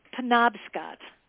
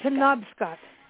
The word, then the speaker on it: Penobscot: It's Penobscot. It's Penobscot. – Penobscot.